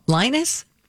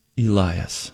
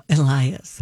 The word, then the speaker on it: Elias: Did you say his name was Linus? – Elias. – Oh, Elias.